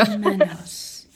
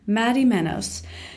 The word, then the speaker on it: Madimenos: It's exactly how you spell it, so it's Madimenos. It's Greek, but you know. – Madimenos. Madimenos.